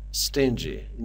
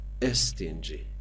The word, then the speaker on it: stingy: عزیزان دقت بکنن، کلماتی که با حرف اس شروع میشن در هنگام تلفظ کردن باید اس رو از حالت ساکن تلفظ کنیم، باید بگیم stubborn. مثلا اینجا stingy، نباید بگیم استینجی، ای نباید اولش بذاریم، اینجا پس میشه stubborn.